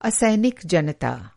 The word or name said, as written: असैनिक